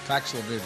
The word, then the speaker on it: Paxlovid: Taking Paxil, he's got mild symptoms. – Paxlovid, excuse me.